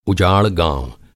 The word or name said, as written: उजाड़